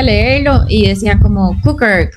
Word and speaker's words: Kuker: De hecho, es K-U-K-E-R, así, Cooker.